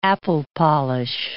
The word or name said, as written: apple-polish